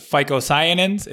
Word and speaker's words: phycocyanins: Another compound I know you like to talk about is the, how do I pronounce it? Phycocyanins. Is that, am I pronouncing that right? – Yeah.